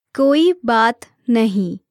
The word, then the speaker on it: koi baat nahi: Koi baat nahi. Let's break it down by syllable. Koi baat nahi.